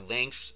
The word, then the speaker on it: Lynx: In command line you can use Lynx or Lynx. They're pronounced the same, but they're spelled differently. First Lynx is Links. The second Lynx is Lynx.